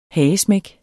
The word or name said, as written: hagesmæk